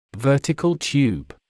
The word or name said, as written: vertical tube